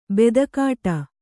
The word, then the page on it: ಬೂದಿಕಟ್ಟೆ: būdi kaṭṭe; kannada